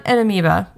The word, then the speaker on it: amoeba: So the eighth one here is that the old water park River Country was actually closed due to a deadly amoeba. – Amoeba, sorry. It's spelled way different than it sounds.